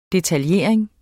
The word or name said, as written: DETALJERING